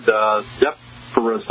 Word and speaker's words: Zephyrus: Zephyr basically is a derivative of a Greek word, Zephyrus, I believe it's pronounced, which is the god of the west wind.